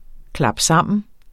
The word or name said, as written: klapsammen